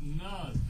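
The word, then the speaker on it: Nudd: We got Nud, the Goblin King.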